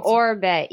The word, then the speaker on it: Oribe: Oh, Oribe. Yeah. – Oribe. Their Gold Lust Nourishing Hair Oil. – Oh my God! Oribe. I don't know if Adrian is he still the head of education for Oribe? – He's a redhead.